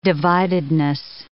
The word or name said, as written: dividedness